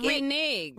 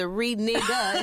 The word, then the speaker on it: renege: Renege. – Renege means, renege is spelled R-E-N-E-G-E in case you thought it was a renega. It's not.